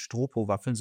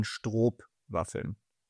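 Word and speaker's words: Stroopwafeln: Ich habe gerade noch mal gecheckt, die Waffen heißen nicht Stropowaffeln, sondern Strobwaffeln. Oder ich weiß nicht, ob ich es richtig ausspreche. STR, Doppel-O-P und dann Waffel.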